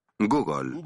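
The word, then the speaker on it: gúgol: Google. G-U-G-O-L. Se escribe así. – No tiene nada que ver con el famoso buscador. Los matemáticos inventaron esa palabra antes.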